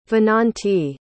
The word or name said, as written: vinanti